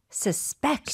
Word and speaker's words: suspect: 스펠링은 똑같은데 사실 강세는 달라요. 그래서 용의자 같은 경우는 suspect. – 아하. – 근데 의심하다라는 동사는 그 두 번째 음절에 넣어주셔야 돼요. Suspect.